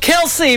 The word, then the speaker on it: Kelce: That is called Kelsey, but it is spelled like Travis Kelsey because that's who she's singing about. K-E-L-C-E.